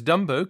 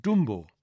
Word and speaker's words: Dumbo: By the way, he says Dumbo can be pronounced the English or the German way, Dumbo.